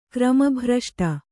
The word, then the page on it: ಕ್ರಮಭ್ರಷ್ಟೆ: krama bhraṣṭe